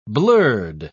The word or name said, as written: blurred